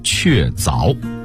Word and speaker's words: 确凿: 类似的修改还有不少，比如确作这个词，最新版的新华字典里已经统一了凿字的读音，它只有凿这一个音，得念确凿。